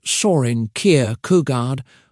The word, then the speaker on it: Søren Kierkegaard: Søren Kierkegaard, pronounced Søren Kierkegaard, was a philosopher who lived in Denmark during the 1800s.